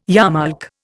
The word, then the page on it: yarmulke: Indian